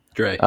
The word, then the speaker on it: Dre: Dre. – Yeah, Crafts with Drew. Crafts with Dre. Oh my God, I already said it wrong. Sorry, Dre. Wow. It's Crafts with Dre, D-R-E.